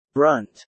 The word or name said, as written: brunt